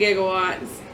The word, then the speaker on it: gigawatts: 1.21 gigawatts. Gigawatts is how you say it. It even says that. – Does it for us? – Yeah, it says Jigga, J-I-G-G.